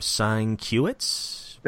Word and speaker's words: Sienkiewicz: Sienkiewicz.